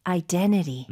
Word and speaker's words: identity: Identity. – Mm-hmm. – Identity. – 어, 쌤 지금 identity라고 하지 않고 T, 첫 번째 나오는 T를 거의 묵음 처리를 하셨네요.